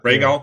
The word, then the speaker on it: Rhaegal: Rhaegal? – Rhaegal.